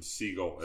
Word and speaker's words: Segal: I think he goes by the pronunciation Siegel opposed to Steven Seagal. – Steven Seagal. – Siegel, all right. – They're spelled the same, but they are pronounced differently.